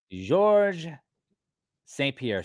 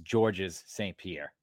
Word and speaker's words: Georges St-Pierre: Wayne Gretzky or Georges St-Pierre, sometimes pronounced Georges St-Pierre.